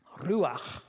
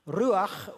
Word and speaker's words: ruach: The Hebrew word, I can't pronounce it, ruach, gotta have that glottal sound, ruach,